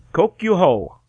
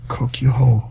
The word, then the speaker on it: Kokyuho: Kokyuho. It's K-O-K-Y-U space H-O. – Kokyuho. – Yes. – Now, this sounds really familiar. Oh, okay. Hey-ho means strategy. – Kokyuho. K-O-K-Y-U-H-O.